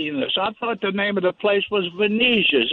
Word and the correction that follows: Venezia: and you pronounce it almost perfectly. Venezia.